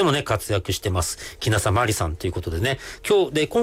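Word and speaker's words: 鬼無里まり: それから、もう 2、 3年経つのかな、シャンソン歌手をやりますっていうことで、歌手名も、キナサマリ。これ、鬼がない里と書いて、キナサ。キナサマリ。マリはひらがなです。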